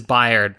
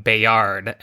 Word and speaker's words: Bayard: And it is Bayard, by the way. I'm very glad I looked up how to pronounce that because I had always called him Bayard, and uh, that is apparently not correct.